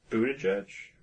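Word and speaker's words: Buttigieg: How is it pronounced, the last name? – Buddha judge.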